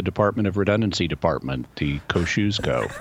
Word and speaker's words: Kosciusko: It's pronounced Kosciusko. – Kosciusko. – Kosciusko. – Gotcha. Cool. – Polish.